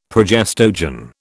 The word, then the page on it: progestogen: British English